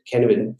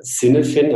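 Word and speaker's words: Cynefin: Äh, es geht um die Stacy-Matrix äh, und es geht um das äh, Kenevin-Modell, äh, kennewin framework oder Sinnefin. Also es gibt da unterschiedliche Aussprachen.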